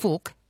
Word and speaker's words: Phuc: I mean, there's a very popular Vietnamese name pronounced Phuc, P-H-U-C.